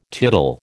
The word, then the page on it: tittle: American English